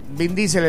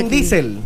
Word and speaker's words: Vin Diesel: ¿Cómo se dice? – Vin Diesel, el protagonista de Triple X. – Vin Diesel.